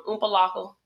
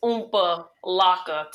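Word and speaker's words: Opa-locka: Oompa Loka. You have to say it like that. And it's Oompa Loka. Two words.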